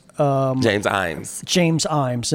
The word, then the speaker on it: Ijames: James Imes. – And that's spelled capital I-J-A-M-E-S, like I, James, yes. – But the J is silent.